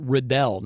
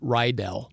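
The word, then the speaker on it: Riddell: It's actually pronounced Rydell, not Rydell.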